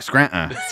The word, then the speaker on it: Scranton: You don't pronounce the T. – Right. Scranton.